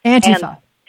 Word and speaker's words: Antifa: Well, I've heard that they put the emphasis on the first syllable. – Antifa.